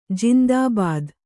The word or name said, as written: ಜಿಂದಾಬಾದ್